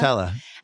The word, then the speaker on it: Nutella: Nutella? – Nutella.